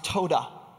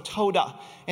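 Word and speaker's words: todah (תודה): And this week we're looking at a word in the Hebrew that is pronounced todah. So todah.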